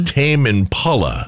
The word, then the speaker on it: Tame Impala: Tame Impala.